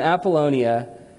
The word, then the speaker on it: Apollonia: It says, Paul and Silas then traveled through the towns of Amphipolis and Apollonia, which I practiced pronouncing those several times. I'm feeling good that I nailed it. Amphipolis and Apollonia, and came to Thessalonica, where there was a Jewish synagogue.